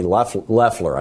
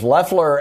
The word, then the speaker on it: Loeffler: And right now, David Perdue and Kelly Loeffler, I guess it's pronounced. I keep wanting to go with the German pronunciation.